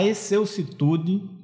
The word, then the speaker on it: excelsitude: Sentes a exclusitude do teu próprio destino. Olha, só para falar que é errado. Falei errado. Sentes a excelsitude do teu próprio destino.